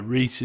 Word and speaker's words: Rhyse: Reese is spelt the Welsh way, R-H-Y-S-E. So yeah.